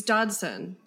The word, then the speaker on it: Dodson: It's Dodson. D-O-D-S-O-N. A strong English name.